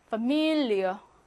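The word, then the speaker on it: Familie: 예를 들면 파밀, 강세가 미 가운데에 있기 때문에 파밀리어. 여러분 파밀리에 아파트 뭐 그런 거 있잖아. 그 파밀리에요. 파밀리어. 이어로 발음하고요.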